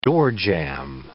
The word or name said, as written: doorjamb